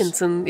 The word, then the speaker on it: Shenzhen: Og der skal de nemlig til den by, det hedder Shenzhen, eller hvordan det nu end udtales. – Shenzhen, ja.